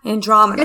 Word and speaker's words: Andromeda: Andromeda.